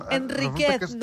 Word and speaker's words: Enríquez: Enríquez, s'ha de pronunciar Enríquez sense títol.